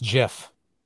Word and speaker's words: GIF: It is pronounced Jeff. It's Jeff. – Okay, Jeff. See, exactly. – I know it's Jeff. It's like peanut butter.